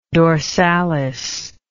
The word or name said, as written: dorsalis